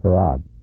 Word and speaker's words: Fard: Farad. That's spelled F-A-R-D.